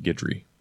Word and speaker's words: Guidry: That's pronounced Guidry for the record.